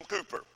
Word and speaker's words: Cowper: His name is William Cooper, and it's spelled C-O-W-P-E-R, but it's pronounced Cooper.